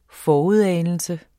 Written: forudanelse

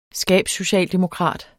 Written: skabssocialdemokrat

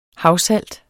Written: havsalt

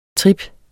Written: trip

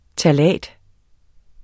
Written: ftalat

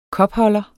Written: kopholder

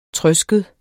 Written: trøsket

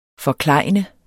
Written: FORKLEJNEDE

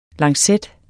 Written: LANCETTERNES

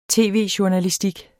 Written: tv-journalistik

